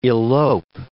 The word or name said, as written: elope